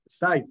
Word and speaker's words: scythe: S-C-Y-T-H-E, if anybody knows how to pronounce that. Scythe, is that how you say it? Okay, the cutting knife. Scythe.